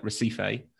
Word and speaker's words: Recife: Does it spell R-E-C-I-F-E, which looks to British eyes like Recife, but in Brazilian Portuguese is pronounced Recife.